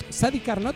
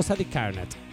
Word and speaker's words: Sadi Carnot: ¿Sadi Carnot o Sadi Carnot? – No, Sadi Carnot. – Pero mira, te digo algo, sí. Porque es Sadi Carnot. Está bien, Sadi Carnot porque no lleva tilde. – No, aparte es un apellido. – ¿Y? – No sé. – Ah, que cada uno lo pronuncia como se le antoja.